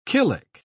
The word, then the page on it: killick: British English